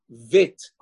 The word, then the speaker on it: Bet: And therefore, we have Re'ayot from Rishonim, Dran, and the Ramban, in Masechet Ketubot, Afchet Amud Bet, they say Meforash, that the way to pronounce a Bet without a Dagesh is Vit, the V sound.